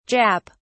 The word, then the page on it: Jap: British English